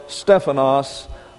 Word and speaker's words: stephanos: It is number 3, the crown, corona, coronet, or stephanos of life. You may know someone named Stephen. S-T-E-P-H-A-N-O-S. And the accent's on the first syllable, Stephanos.